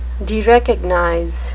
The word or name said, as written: derecognize